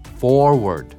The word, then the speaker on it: forward: for. で、最後はまあストレスかかってないけど、やっぱり下の緊張感ね。 word, word. forward.